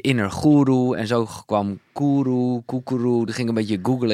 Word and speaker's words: koekeroe: Dus K-O-E. Koekeroe. – Zo spreek ik het dus wel.